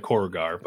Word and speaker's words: Korugar: Sinestro was a great Green Lantern who, it was revealed, was a dictator on his home world of, how do you even pronounce that? Koruger? – I always call it Korugar. – Korugar, sure, Korugar.